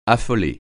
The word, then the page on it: affoler: French